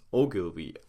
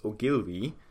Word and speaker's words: Ogilvy: I, I googled it just to find out, especially for the second part, because I actually thought it would be pronounced Ogilvy, but that doesn't make sense now that I know it, and Mather.